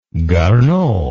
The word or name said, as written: garneau